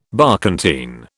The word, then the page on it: barkentine: British English